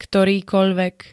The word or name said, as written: ktorýkoľvek